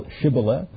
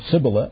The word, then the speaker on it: Shibboleth: That as they were testing the dialects of certain people as they crossed the river, then said they unto him, say now Shibboleth, and he said Sibboleth.